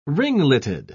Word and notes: ringleted: British English

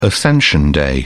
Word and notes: ascension-day: British English

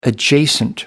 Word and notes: adjacent: American English

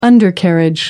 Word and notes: undercarriage: American English

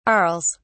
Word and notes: arles: British English

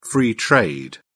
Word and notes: free trade: British English

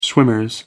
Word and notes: swimmers: American English

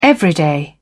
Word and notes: everyday: British English

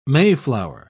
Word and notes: mayflower: British English

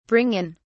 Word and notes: bring in: British English

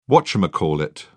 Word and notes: whatchamacallit: British English

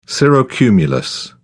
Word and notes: cirrocumulus: British English

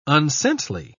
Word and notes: unsaintly: British English

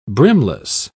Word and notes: brimless: British English

